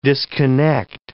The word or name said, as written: disconnect